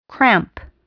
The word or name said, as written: cramp